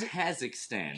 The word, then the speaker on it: Kazakhstan: So Kazakhstan or he's, Kazakhstan? – Kazakhstan. So whichever one you want to say it. – So he's. – Yep, I put the wrong emphasis on the wrong syllable.